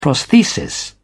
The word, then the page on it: prosthesis: British English